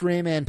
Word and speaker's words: Rayman: We're basically the best Rayman players that ever existed.